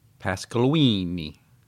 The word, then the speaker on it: Pasqualini: Pasqualini. – Oh, I forgot. I didn't say it like Mario or Luigi from Nintendo. Or Chef Boyardee. – Chef Boyardee, yeah. – Which is how you say it.